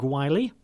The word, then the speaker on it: Wiley: And you can hear more from Craig Wiley, rhymes with smiley, at 7 o'clock this evening.